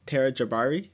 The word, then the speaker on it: Tara Jabbari: Tara, thank you so much for Tara Jabbari.